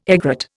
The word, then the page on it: egret: Indian